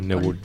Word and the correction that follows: neblina: Neblina, se dice.